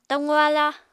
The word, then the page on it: taqu-aláa: Apatani